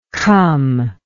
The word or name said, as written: cum